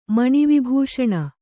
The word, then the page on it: ಮಣಿವಿಭೂಷಣ: Kannada